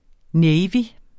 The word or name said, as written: navy